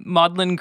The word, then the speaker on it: Magdalen: I kind of want to talk about the Maudlin Grimoire. It's spelled like Magdalene,